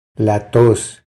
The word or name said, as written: La tos